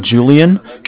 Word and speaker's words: Julian: Okay, my name is Julian. It's J-U-L-I-A-N.